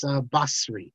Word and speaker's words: Basri: But so the Sadi is more like that, not Sadi. It's not the same. It's not a sin. It's not a samech. It's somewhere. It's sort of in the middle. And you can hear this today. Just the Syrians, they all pronounce it properly. And so his name is, even though it's with a Sadi, it's Basri.